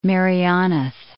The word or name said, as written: Merioneth